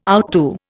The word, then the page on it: outdo: Indian